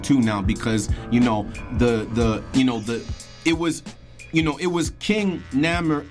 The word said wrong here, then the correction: Narmer: I want to pronounce the name right, King Narmer, right,